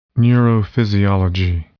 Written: neurophysiology

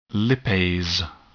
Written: lipase

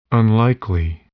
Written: unlikely

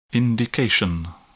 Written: indication

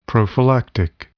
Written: prophylactic

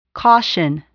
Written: caution

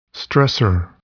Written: stressor

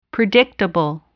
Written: predictable

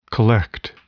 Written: collect

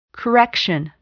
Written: correction

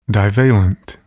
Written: divalent

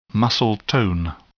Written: muscle tone

